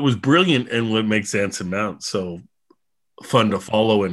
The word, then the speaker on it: Anson Mount: I am saying that right, Anson Mount. – Yes, you are.